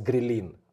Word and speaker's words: ghrelin: It's called, in Russian, it's ghrelin. I don't know how, that's how it's spelled. – Uh-huh. – Is it ghrelin?